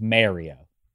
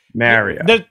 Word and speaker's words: Mario: Because one Mo Damashek once said to Mario Lemieux, "People sometimes say Mario. I want to make sure I say your name correctly. How do you say it? What did 66 say?" He didn't say 66. He said, "It's Mario."